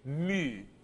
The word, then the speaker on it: mu: It's the letter for M, M, but it's mu, mu.